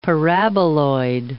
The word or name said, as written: paraboloid